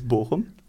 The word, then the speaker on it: Bochum: Du er nemlig fra byen som ikke heter Bochum, men Ja, det var meg som har masta litt på Twitter ja. innimellom. Det uttales Bochum. Borom? En, eh, O-en i eh, Borom uttales egentlig som den norske Å-en. egentlig.